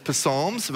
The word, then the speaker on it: Psalms: And some people pronounce it Psalms. They think they're... It's Psalms, but the P is silent, as it always should be in our lives.